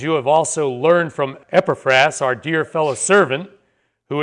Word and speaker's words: Epaphras: First of all, Epaphras. Pronunciation is probably that way. Epaphras, although he's also called Epaphras.